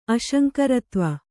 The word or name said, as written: ಅಶಂಕರತ್ವ